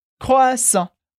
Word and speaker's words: croissant: E como a gente viu na aula, o T que aparece no final da palavra não é pronunciado, por isso a gente sempre vai ter croissant.